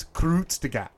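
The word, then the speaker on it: Kurzgesagt: But it's K-U-R-G-S-T-D. – You asked for the pronunciation. Yeah. It's Krutzgat.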